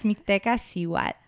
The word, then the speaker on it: Mictlantecuhtli: Yeah, it's Mictlantecuhtli.